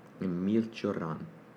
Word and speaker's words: Emil Cioran: Emil Cioran.